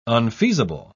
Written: unfeasible

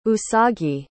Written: usagi

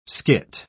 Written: skit

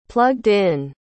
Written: plugged-in

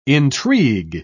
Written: intrigue

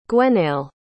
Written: gwenaël

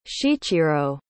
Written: Shichiro

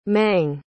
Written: mang

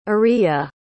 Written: ariya

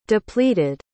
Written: depleted

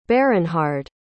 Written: berinhard

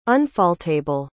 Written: unfaultable

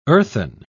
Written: earthen

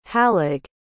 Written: halig

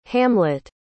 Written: hamlett

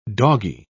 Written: doggy

